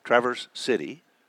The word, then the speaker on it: Traverse City: We're going to be here the next few days, and then we're heading up to Traverse City. Did I say that correctly? – Yep.